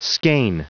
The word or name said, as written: skein